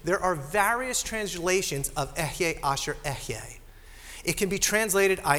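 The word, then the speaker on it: Ehyeh Asher Ehyeh: In Hebrew, this is how it would have been pronounced. Ehye Asher Ehye.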